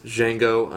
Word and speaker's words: Django: He could make a movie with stick figures, and I would pay probably $10 to see it, is Django Unchained, The D is Silent, starring Jamie Foxx and a white supremacist slave owner, Leonardo DiCaprio.